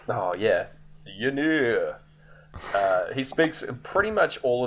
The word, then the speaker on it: Ynyr: His name is Ynir in the film, I think is how it's said. It's spelt Y-N-Y-R, which is awful.